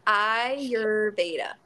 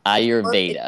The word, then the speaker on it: Ayurveda: Yeah, so it's Ayurveda. – Ayurveda. – It doesn't sound how it's spelled, but it's like an I instead of an A.